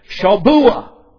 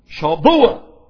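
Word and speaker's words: shabuwa: S-H-A-B-U-W-A. S-H-A-B-U-W-A, which is pronounced something like this. Shabuah. Shabuah.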